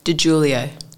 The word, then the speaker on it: Di Giulio: DiGiulio.